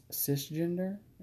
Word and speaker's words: cisgender: I identify as cisgender.